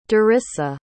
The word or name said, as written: darissa